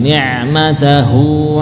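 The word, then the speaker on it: ni'matahu (نعمته): Ni'matahu 'alaika. Coba dikonsentrasikan pengucapan ha setelah huruf ta. Ni'matahu 'alaika. Dari pangkal tenggorokan.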